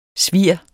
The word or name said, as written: svir